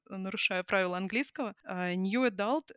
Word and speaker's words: adult: Во-первых, я перед выпуском еще раз посмотрела, как произносится в русскоязычной благосфере э, new adult и young adult. Как-то э, склоняются ближе к ударению на последний слог.